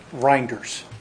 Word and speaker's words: Reinders: Little German, but it's Reinders. – Reinders. – Well, my name is Stephen Reinders.